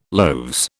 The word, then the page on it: loaves: British English